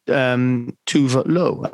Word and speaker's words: Tove Lo: Anyway, but we saw the name, John and I saw the name Tove Lo, and that's how, by the way, that is the Swedish way of pronouncing it.